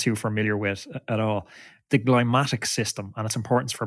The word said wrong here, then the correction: glymphatic: Can you explain, firstly, am I pronouncing that right? And secondly, what the hell it is and how it works? – Yeah, you're talking about the glymphatic system.